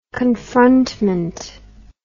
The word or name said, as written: confrontment